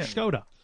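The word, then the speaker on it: Škoda: Skoda. – It's pronounced Skoda. – With a H.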